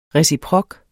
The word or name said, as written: reciprok